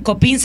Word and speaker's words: COPINH: COPIN se escribe COPINH. Entonces, en el Twitter pueden encontrar @COPIN, acabado en H, Honduras.